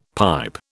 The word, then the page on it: pipe: British English